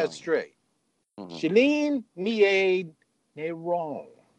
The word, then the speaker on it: Negron: In most of the world, that G is silent. Nairon.